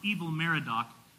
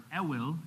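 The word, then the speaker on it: Evil-Merodach: After Nebuchadnezzar died, he was first replaced by one of his sons named, it's pronounced Evil Merodach. His name was not Merodach and he was really evil, okay? If we were to really say it, it would be Evil or Ewil.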